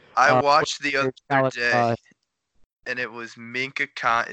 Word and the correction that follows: Mina Kimes: Is that how you say her name? – Mina Kimes.